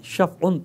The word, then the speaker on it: شفع: شفع فے ساکن ہے، فے پہ سکون ہے، زبر کے شفع، عین کو ہم نے تلفظ کر دیا جیسے کرنا چاہیے، اور اس کے بعد ہے وتر، ٹھیک ہے نا، دو باتیں۔ شف کا معنی ہے جفت، جوڑ، جوڑا۔ شف کا معنی کیا ہے؟